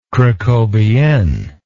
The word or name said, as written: cracovienne